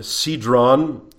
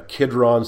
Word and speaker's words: Cedron: and he is leading them over the brook Cedron, and sometimes it's referred to as a Kidron, Cedron spelled with a C, Kidron with a K. It's the same reference.